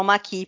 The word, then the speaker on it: Maquis: Ah, é por isso que eles não falam S no final, então eles falam maqui. No francês, as palavras que terminam em S, eles não pronunciam S. – Isso, o é S é mudo.